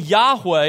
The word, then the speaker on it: Yahweh: Because of that, it's clear that Solomon is here contrasting trusting Yahweh. And by the way, when you see the word Lord in all caps in your Bible, that's God's personal name. That's Yahweh is how it's pronounced in Hebrew.